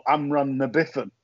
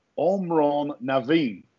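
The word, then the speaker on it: Amhrán na bhFiann: And he said basically it is pronounced Omron Naveen, and translated it means the soldier's song.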